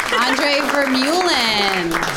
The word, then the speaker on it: Andree Vermeulen: Andree Vermeulen.